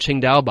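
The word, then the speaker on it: Tsingtao: The TS that we see on Qingdao bottles is within the Wade-Giles system.It is supposed to be pronounced as the CH sound, Qing, Qingdao, right? But for some reason, they chose to spell it with a TS.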